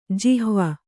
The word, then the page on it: ಜಿಹ್ವ: jihva